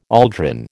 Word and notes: aldrin: American English

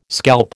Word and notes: scalp: American English

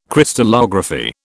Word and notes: Crystallography: British English

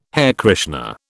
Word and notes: Hare Krishna: British English

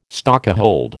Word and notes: stokehold: American English

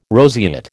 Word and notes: roseate: American English

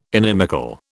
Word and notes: inimical: British English

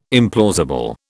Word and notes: implausible: British English

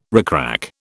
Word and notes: rickrack: British English

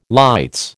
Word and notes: lights: American English

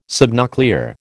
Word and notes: subnuclear: American English

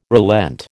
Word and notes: relent: American English

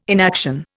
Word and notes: inaction: Indian

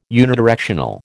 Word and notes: unidirectional: American English

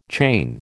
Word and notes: chain: American English

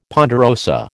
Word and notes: ponderosa: American English

